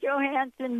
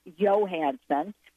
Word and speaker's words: Johansson: Another Scarlett Johansson movie. And by the way, my Scandinavian friend said, do not pronounce her name like that, please. It's Scarlett Johansson.